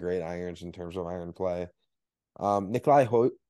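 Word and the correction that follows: Højgaard: Hoyard. – Hoyard, thank you.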